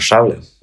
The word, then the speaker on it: шавля: А, ну, правильно скажет только носитель. Я считаю, что правильно шавля.